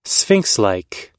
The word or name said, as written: sphinxlike